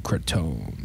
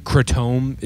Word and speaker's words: Kratom: And Hamilton's like, well, in fucking Thailand, like their pronunciation for it, like Kratom is closer to what the actual pronunciation is. Because it's like, it's Thai. So it's like, Kratom.